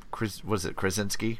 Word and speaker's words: Krasinski: I heard wonderful things about that, and I heard an interview with John Krasinski.